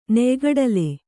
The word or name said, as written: ನೆಯ್ಗಡಲೆ